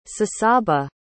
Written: sassaba

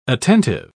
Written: attentive

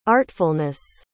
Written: artfulness

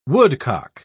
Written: woodcock

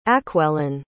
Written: acwellen

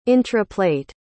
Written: intraplate

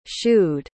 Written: shooed

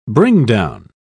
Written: bringdown

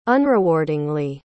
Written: unrewardingly

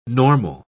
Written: normal

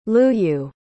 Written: luyu

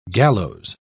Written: gallows

